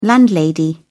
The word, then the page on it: landlady: British English